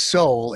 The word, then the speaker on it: Sowell: You just mispronounced his last name. It is Sowell, and of course the left wing satirically wrote about him as, of course, a renegade African-American, and they called him Sowell Brother.